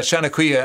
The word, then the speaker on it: Seanchoiche: And people can go to the website of that, Seán Coiche, and it's spelled S-E-A-N-C-H-O-I-C-H-E.